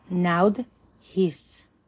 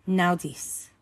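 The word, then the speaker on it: naudiz: the phonetic value is N, pronunciation naud his, naudis. The summary is need, coming through challenge, stepping forth into manifestation.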